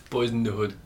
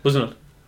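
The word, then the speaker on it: Boyz n the Hood: How do I say it? Boys in the Hood? – Boys in the Hood. – In the Hood. – Just say it as quickly as can. Buzzin' Hood.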